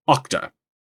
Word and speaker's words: Okta: Thanks to listener Chris, who tells me I was mispronouncing Okta.